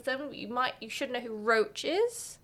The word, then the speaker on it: Roche: Yorveth, Yeah, it is Roach. The guy's name is Roach too, but it's spelled different. R-O-C-H-E.